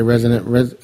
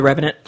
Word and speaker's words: Revenant: Well, you were starting to talk about, I can never say resident, how you say it? – The Revenant. – The Revenant. – The Revenant. – I always want to call it resident.